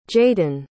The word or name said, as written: jaydin